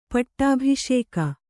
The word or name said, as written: ಪಟ್ಟಬಾಸಿಗ